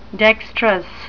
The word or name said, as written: dexterous